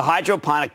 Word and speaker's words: hydroponic: It's called Grow Generation, the hydroponic gardening chain that sells everything you need to grow cannabis at scale. Yes, it's the Tractor Supply of pot. Hydroponic rhymes with chronic.